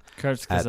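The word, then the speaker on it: Kurzgesagt: I know you can pronounce that channel very well. – Kurt Skazat. – Very good.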